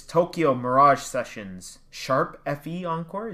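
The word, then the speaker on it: Tokyo Mirage Sessions ♯FE Encore: It's Tokyo Mirage Sessions, Sharp FE Encore, is that how it's pronounced? – Correct.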